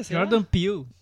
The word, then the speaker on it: Jordan Peele: O filme, então, é dirigido pelo Jordan Peele, é assim que pronuncia? – Jordan Peele. Jordan Peele, é.